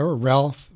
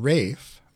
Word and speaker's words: Ralph: I think we'll do this next, written by, in America, Ralph Vaughan Williams. In England, Rafe Vaughan Williams. That's how it's pronounced in England.